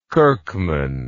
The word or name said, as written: kirkman